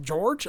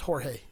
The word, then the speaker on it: Jorge: I was like, people are like, George? I'm like, no, it's Jorge.